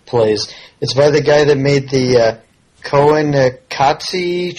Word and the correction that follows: Koyaanisqatsi: trilogy. If I'm saying that right. – Koyaanisqatsi.